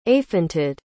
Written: aefentid

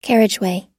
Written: carriageway

